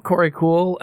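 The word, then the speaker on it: Korey Kuhl: I'm Corey Cool everywhere on the internet.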